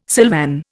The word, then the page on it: silvan: Indian